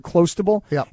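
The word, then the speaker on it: Clostebol: By the way, the term, this whatever this product is that he took, Clostable, it sounds like close to ball.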